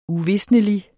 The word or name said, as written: uvisnelig